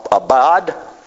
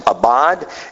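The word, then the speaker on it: abad (עבד): Actually, the word servant is abad. That's how it's pronounced, abad.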